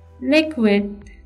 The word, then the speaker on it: liquid: liquid, liquid, liquid. The stress is on L.